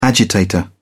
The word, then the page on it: agitator: British English